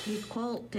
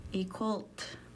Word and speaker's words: Equalt: Equalt is how you say cry in Kixt. Equalt.